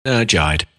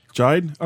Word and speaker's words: Jide: And it's a product from this company called, how would you pronounce that? Jide or GD or? – Jide. – Jide? Okay. – Yeah. It's, as in Hyde, but with a J.